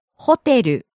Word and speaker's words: ホテル: Starts high, falls on the second syllable, te, stays low. – ホテル。